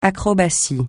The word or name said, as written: acrobatie